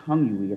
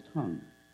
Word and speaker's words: tongue: Whenever you see an English word that ends with UE, the UE is silent. So it's not vague UE, it's vague. It's not tongue-you-y, it's tongue.